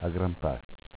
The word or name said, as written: Agrampass